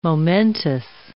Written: momentous

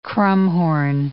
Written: krummhorn

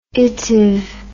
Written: itive

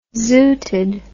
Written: zooted

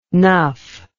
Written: nuff

